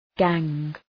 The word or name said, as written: gang